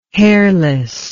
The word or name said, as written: hairless